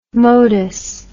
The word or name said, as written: modus